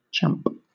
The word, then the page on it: champ: British English